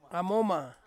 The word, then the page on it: amoma: Ansus